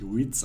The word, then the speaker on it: REITs: Also es wird tatsächlich REITs ausgesprochen und nicht irgendwie REITs oder andere Varianten.